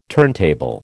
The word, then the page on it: turntable: American English